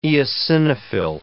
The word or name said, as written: eosinophil